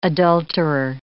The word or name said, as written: adulterer